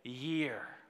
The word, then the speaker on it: Jair: His name is Jair, or as you would pronounce it in the Jewish way, is Year is his name.